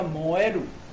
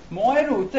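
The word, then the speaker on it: 萌える: 萌在日语当中叫摩埃鲁，摩埃鲁这个那个什么。读音跟燃就是燃烧起来，烧起来是一样的。摩埃鲁，当时有些人在网上为了体现自己对某些女性的喜爱，他说哇，看到这个女性，我整个哇，燥热的浑身都要烧起来了，就叫摩埃鲁。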